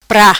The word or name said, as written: Pra